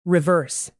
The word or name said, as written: reverse